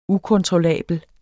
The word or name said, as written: ukontrollabel